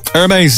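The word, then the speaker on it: Hermès: Hermes, is that how you pronounce that? – Yeah.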